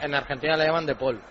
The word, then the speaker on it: De Paul: En Argentina se pronuncia como se dice, como se escribe, De Paul, como en castellano.